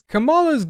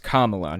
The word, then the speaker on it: Kamala: Kamala's gulag. Okay, first of all, her name's pronounced Kamala, not Kamala.